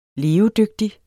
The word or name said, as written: LEVEDYGTIG